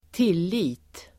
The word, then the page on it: tillit: [²t'il:i:t]